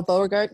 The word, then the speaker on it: Beauregard: There's one in Louisiana, Camp, do you know how to pronounce it? Beauregard? – Oh, Beauregard, yeah.